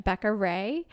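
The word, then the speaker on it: Becka Rae: It's B-E-C-K-A and then R-A-E.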